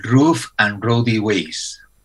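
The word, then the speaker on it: Rough and Rowdy Ways: Bueno, han grabado el disco un poco a rebufo de la gira de este disco, que es pronunciar jodido, Raw and Rowdy Ways, a ver, no sé cómo lo dirías tú. – Sería Roof and Rowdy Ways.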